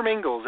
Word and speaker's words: Mingils: Yeah, Google my name, Peter Mingles, M-I-N-G-I-L-S. Make sure you spell it right. M-I-N-G-I-L-S.